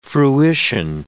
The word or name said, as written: fruition